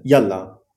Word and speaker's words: yalla: Eh, tiene una entonación particular, eh, o sea, me suena que son pues dos sílabas, ya, la, ya, la. Mm. Entonces la entonación, por ejemplo, lo más fuerte es ya o la. Mm. Eh, no, sobre la primera sílaba yalla.